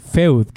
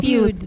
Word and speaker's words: Feud: The Sinner, Big Little Lies, Fargo, Feud, que obviamente está mal pronunciado. – Feud. Feud, gracias.